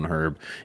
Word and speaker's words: herb: herb is the best way to go about it. Yeah, I pronounced the H in herb.